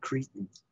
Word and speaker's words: Chrétien: I pronounce that Cretan. It was under the Cretan government that this took place.